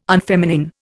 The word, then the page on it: unfeminine: Indian